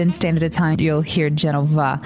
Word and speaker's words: Genova: This has been a big question because, you know, in English we say Genoa. – If you can pronounce the V, you can say Genova. If you cannot, just say Genoa.